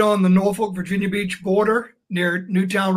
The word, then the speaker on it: Norfolk: Norfolk, Virginia.